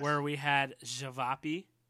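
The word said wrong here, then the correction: ćevapi: How do you pronounce that one? – Chivapi. – Chivapi. Okay.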